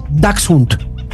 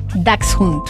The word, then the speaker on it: Dachshund: No se dice, el nombre del perro salchicha es Dachshund. La mayoría de la gente dice que es Dachshund. – Otra vez dilo, ¿cómo es? – Es Dachshund. – Dachshund.